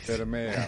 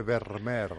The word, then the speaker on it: Vermeer: Ah, Vermeer. Con la R, igual que en neerlandés siempre Vermeer.